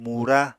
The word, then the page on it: mura: Apatani